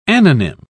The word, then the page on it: anonym: British English